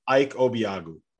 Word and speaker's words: Ike Obiagu: Ike Obiagu.